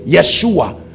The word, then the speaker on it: Yeshua: That's where they get Yeshua, because they pronounce Y for J, Yeshua.